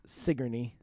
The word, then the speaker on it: Sigourney: It's spelled Sigourney, like Sigourney Weaver, mm-hmm. – but it's pronounced Sigourney.